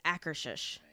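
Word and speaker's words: Akershus: I think it's Akershish. Yeah, it's just Akershish.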